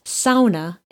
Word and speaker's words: sauna: sauna is pronounced sauna, and you will be looked at funny.